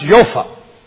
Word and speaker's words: Joppa: And he went down, now we would say Joppa, it's pronounced Jopha, and it means beautiful.